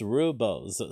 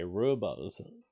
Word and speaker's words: rubles: Because the ruble has been dropping in value across the world, and so it's basically eventually it's pronounced rubles. Say go away. The same way you would say ruby. Okay, rubles.